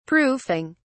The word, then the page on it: proofing: British English